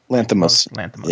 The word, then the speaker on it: Lanthimos: So the next movie is the latest from, and I'm going to butcher his name, Yorgos Lanthimos. – Lanthimos.